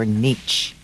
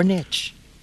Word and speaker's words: niche: We're going to talk to him about finding your niche. Or niche. – Niche. – You may pronounce it either way. – I say niche, but that's because I'm an elitist.